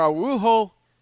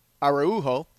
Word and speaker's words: Araujo: Alexi, Brendan Davis, Willie Calhoun, Grant Holmes, Jarrell Cotton, Frankie Montas, Caleb Dierks, Phil Pfeiffer, Zachary Bird, Hector Olivero, Paco Rodriguez, Victor Araujo, I said that wrong. Araujo.